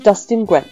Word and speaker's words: Dustin Nguyen: So Dustin Young was... Oh, actually, it's pronounced Dustin Nguyen.